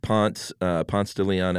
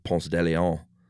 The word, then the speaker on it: Ponce de Leon: And, you know, at Ponce de Leon Avenue here in Atlanta, everyone that doesn't live in Atlanta probably laughs that we pronounce it that way instead of Ponce de Leon.